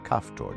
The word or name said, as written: Caphtor